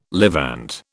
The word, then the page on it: Levant: British English